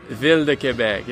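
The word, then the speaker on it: Ville de Québec: And I'll see you in, how do you say it? Via de Quebec? – Ville de Quebec, yeah.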